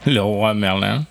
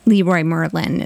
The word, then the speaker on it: Leroy Merlin: Leroy Merlin. – It's just Leroy Merlin is what it is. – Yeah. – But the French way.